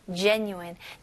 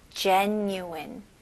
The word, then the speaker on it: genuine: genuine. 네, 스펠링은 G지만 J 발음으로 해주셔야 됩니다. genuine. genuine, okay? 여기 new-in, n-u-i 부분에는 new-in, n-y-o-o 느낌으로 한번 해보세요. genuine, genuine, very good.